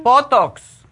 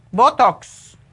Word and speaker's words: Botox: Botox. B-O-T-O. T-O-K. Botox.